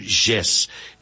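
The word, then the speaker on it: Jess: Não importa qual você use, é Pedro Gess. Gess se escreve com J-E-S-S, J-E-S-S, 158, em Araucária.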